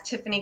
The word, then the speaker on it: Tiffanie: So the nice thing is I am on just about all social media as Tiffany Kellogg, T-I-F-F-A-N-I-E, Kellogg, K-E-L-L-O-G.